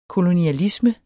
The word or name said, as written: KOLONIALISMEN